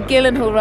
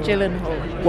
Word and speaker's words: Gyllenhaal: Donnie Darko was played by Jake Gyllenhaal. – Thank you. – Okay, it's definitely Gyllenhaal rather than Gyllenhaal.